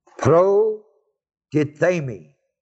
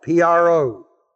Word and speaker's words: protithemi: The Greek word for set forth is the word protithemi, spelled protithemi. T-H, long E-M-I.